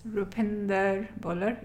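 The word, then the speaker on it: Roopinder Bhullar: Rupinder Bhullar. It's spelled R-O-O-P as in Paul,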